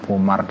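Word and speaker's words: Umar (عمر): Kalau kata Umar ain mem rok ro setelah rok gak ada wawunya itu bacanya Umar ya.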